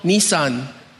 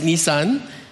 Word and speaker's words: Nisan: It's called Nisan, not the car. Spelled with one S, Nisan.